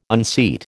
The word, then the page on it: unseat: American English